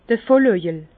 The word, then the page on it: d'r Vollöjel: Bas-Rhin; Herrlisheim